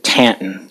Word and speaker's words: Taunton: I grew up in a nearby industrial town called Taunton, Massachusetts, or as the locals pronounce it, Tanton.